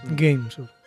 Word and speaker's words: Game: Games. – Game. Soy español, lo puedo pronunciar así si quiero.